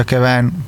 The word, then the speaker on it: Kevin: che poi si pronuncia Kevin perché è francese,